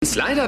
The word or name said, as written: Slider